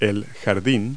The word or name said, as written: backyard